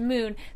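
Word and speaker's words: Mun: It's pronounced moon, so technically it's sun and moon.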